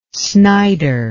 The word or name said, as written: snyder